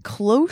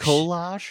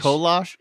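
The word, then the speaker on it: kolache: It's like cloche? – Kolosh. – Kolosh? – Kolosh.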